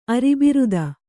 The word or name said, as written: ಅರಿಬಿರಿದು